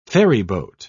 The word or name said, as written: ferryboat